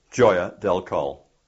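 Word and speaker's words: Gioia del Colle: What del Col? – Gioia del Col. – How do you say it? It's Gioia. Does that sound like it? Gioia del Col.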